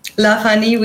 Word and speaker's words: Lovehoney: C'était Love Honey et, euh, et Je crois que vous prononcez Love Honey. – Love Honey, oui. c'est vrai.